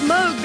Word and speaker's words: Moog: It's pronounced Moog because it's Dutch.